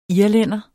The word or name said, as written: irlænder